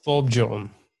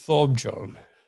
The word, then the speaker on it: Thorbjørn: Thorbjørn. – Just once more, please. – Thorbjørn. – Just again, please, for the viewers out there. – Thorbjørn.